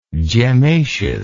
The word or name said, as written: gemmaceous